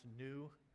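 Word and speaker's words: nu: A few of them they didn't use because one of them is actually pronounced new. And so they didn't want people to just think it was new variant.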